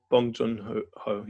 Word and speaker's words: Bong Joon-ho: I said his name right. – Yeah, Bong Joon-ho. Yeah, perfect.